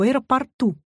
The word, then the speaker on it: аэропорту: Обратите внимание на слово «аэропорт». Ударение в нем везде неподвижно. Аэропорта, аэропортов, в аэропортах. Но есть одно исключение. В аэропорту.